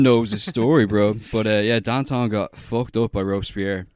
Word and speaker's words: Danton: I watched Danton, or Danton, as it's pronounced in French.